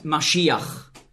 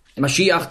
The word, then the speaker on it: Maschiach: In meiner Bibel steht Messias, weil im Grundtext hier das Wort Maschiach, so ist die hebräische Aussprache, zu finden ist. Messias ist eine sprachliche Anpassung ans Griechische. Nicht wahr? Im Griechischen konnte man den Sch-Laut nicht aussprechen. Und da war es also nicht möglich für Griechen einfach so Maschiach zu sagen und schon gar nicht zu schreiben, weil im Griechischen gibt es keinen Sch-Laut.